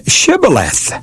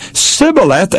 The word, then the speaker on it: Shibboleth: Well, what they did was make the person pronounce the word Shibboleth. And if that person said Sibboleth instead of Shibboleth, they knew it was the enemy, and they slew them as traitors.